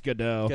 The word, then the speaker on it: Gadot: I think it's Gadot. – Gadot? – Gadot. – Gadot? – Israeli. – Yeah, Silent Gadot. T. – Oh, because I always go with Silent T.